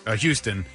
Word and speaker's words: Huston: Is it Huston? – Houston. – Is it Houston? It's spelled wrong.